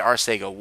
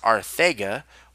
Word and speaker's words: Arcega: Arcega Whiteside to the Eagles, and it's actually pronounced Arthega with the TH instead of a C for Sega.